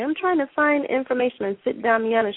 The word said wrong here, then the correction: Sit Damiano: She said, you mean Sit Damiano? And, you know, because I was pronouncing it wrong, she didn't know what I was saying for weeks, and finally she figured it out, and I said, Sit means lady, right?